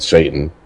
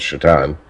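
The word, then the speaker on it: Šatan: Because, one, his last name was spelled Satan, but pronounced Shatan.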